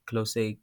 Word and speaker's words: Klose: Is called Keith Close, K-L-O-S-E. Yeah, I don't know how to say it. Close. Yes, he's a dope guy.